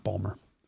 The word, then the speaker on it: Baltimore: It's pronounced Balmer for those people who live there.